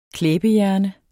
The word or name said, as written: klæbehjerne